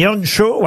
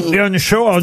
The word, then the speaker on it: Earnshaw: Et c'est vrai que c'est un célèbre roman international, mondial, un gros succès mondial, avec dans ce roman la famille Earnshaw. Ouais, je le dis bien, là, ça vous va? – Oui. – Earnshaw.